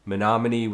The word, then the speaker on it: Menominee: So Harry Miller was born December 9th, 1875 in Menominee, Wisconsin. I think I pronounced that right. – Yep.